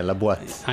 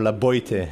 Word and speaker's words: La Boite: I knew I was going to screw that. I pronounced it right. But or La Boite, as many people. – Yeah, we're going to attempt to correct everybody's French.